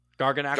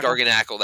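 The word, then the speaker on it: Garganacl: Garganacle? – Garganacle. – Garganacle, that's it, yeah.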